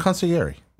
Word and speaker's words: Consigliere: Consigliere. – Consigliere? – Concierge. – There's a G in there.